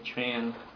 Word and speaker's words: Tran: Nick Tran is proposed manager of record. – Good afternoon. – Good afternoon. – Could you please state your name for the record and spell your last name? – Nick Tran, last name is spelled T-R-A-N.